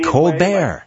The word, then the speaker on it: Colbert: And I might mention to you, I pronounce it the French-Canadian Colbert. – Way, Steve Colbert.